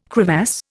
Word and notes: crevasse: Indian

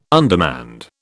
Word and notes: undermanned: British English

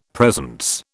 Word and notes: presence: British English

